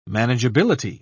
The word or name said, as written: manageability